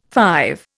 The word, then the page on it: five: Indian